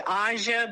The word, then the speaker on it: Aja: It's actually Aja, but Aja.